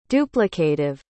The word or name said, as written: duplicative